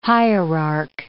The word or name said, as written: hierarch